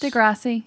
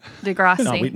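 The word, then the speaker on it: de Rossi: Degrassi. – Thank you. – Degrassi.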